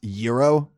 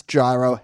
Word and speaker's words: gyro: So last week we spoke about gyro and how can it possibly be pronounced gyro, which doesn't compute. Well, here's why. Greeks say the G differently than we do in English.